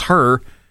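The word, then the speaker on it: H.E.R.: It's spelled H period E period R period, but it's her. And it's actually an acronym. It stands for Having Everything Revealed.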